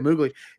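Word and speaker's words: Mowgli: You know, you've got all these things that are unsettling, and you know, maybe Moogly is a little creepy. Is it Moogly? Did I say it right? Yeah, Moogly.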